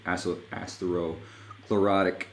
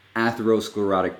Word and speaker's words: atherosclerotic: And endothelial dysfunction is a very good predictor of future cardiac events like ASCVD, atherosclerotic, I'm not saying it right, atherosclerotic disease. There you go.